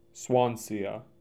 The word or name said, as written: Swansea